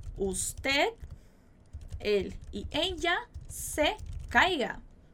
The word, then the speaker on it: caiga: Então, nadie quiere que yo me caiga. Nadie quiere que tú te caigas. Muito cuidado com a pronúncia dessa palavra, tá? Caiga. Uma boa ênfase aí nesse I que você tem aí pra falar essa palavra.